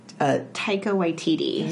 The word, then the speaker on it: Taika Waititi: He's the New Zealand Taika Waititi.